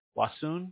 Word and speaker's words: Wauseon: How do I say that? Wasun? Is that right? – Yeah. W-A-U-S-E-O-N. – Yeah, Wasian, okay.